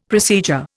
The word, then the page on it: procedure: Indian